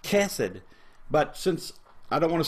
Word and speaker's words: chesed: It's hesed. If you pronounce it correctly, it begins with a guttural. It's more chesed.